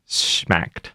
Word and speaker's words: Shmacked: Party promotion company I'm Schmacked. I do hope I'm pronouncing that correctly. – Can you spell Schmacked for me? – I will. S-H-M-A-C-K-E-D. – Okay. – Schmacked.